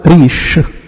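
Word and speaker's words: ريش (ריש): حرف الره في اللغة العبرية ينطق ريش ومعناه رأس.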